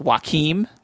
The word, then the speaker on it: Joachim: It's a film entitled Oslo, August 31st, directed by, I'm going to guess his first name's pronounced Joachim, like Joaquin Phoenix, Joachim Trier.